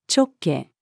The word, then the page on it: 直径: female